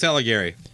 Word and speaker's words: Alighieri: Dante's Allegri's. Uh, how do you say that? Alle- I think it's Alighieri.